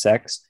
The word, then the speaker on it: CeX: There's a secondhand disc and game shop called CEX, pronounced sex in the UK.